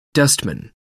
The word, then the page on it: wingman: American English